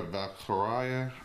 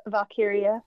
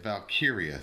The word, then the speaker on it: Valkyria: Well, we had the new addition, Lara Valkyria. I cannot say her name, but Valkyria. – Yeah, Valkyria. Thank you.